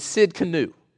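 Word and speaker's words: Tsidkenu: I think the best pronunciation is Sid Canu.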